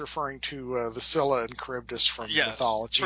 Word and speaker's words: Charybdis: But he gave us a story in which Aquaman loses his hand to how do you pronounce the enemy's name? The villain? – Charybdis.